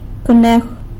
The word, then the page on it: conejw: sanlucasquiavini